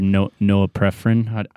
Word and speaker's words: norepinephrine: And your norepinephrine. I don't know how you say it. – Norepinephrine.